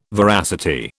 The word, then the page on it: veracity: British English